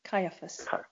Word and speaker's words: Caiaphas: But the thing about it is, as I grew older, how do you pronounce his name? Is it Caiaphas, the head Jewish? – Caiaphas.